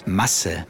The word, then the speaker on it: Masse: For example, Straße, which is street, or Maß, which is measure, versus Masse. Masse is amount. It's spelled M-A-S-S-E. And that's a short vowel sound. So, Straße, Masse. – Straße, Masse. Mm-hmm.